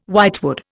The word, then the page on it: whitewood: Indian